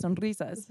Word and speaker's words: SONRIZAS: Do you roll the R or no? – When I'm too drunk, SONRIZAS. No. But like normally, yes. I'll be like SONRIZAS. – We got SONRIZAS here. – Is that the correct way? – SONRIZAS. – Okay, to roll it.